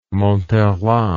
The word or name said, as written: Montherlant